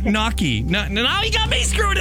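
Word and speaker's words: gnocchi: It's knocky. – What? – It's knocky. The G is silent.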